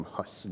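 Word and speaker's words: Hus: I was recently reading through the works of John Huse, or Huse is the correct pronunciation. In more Western English, we call him Huss, John Huss.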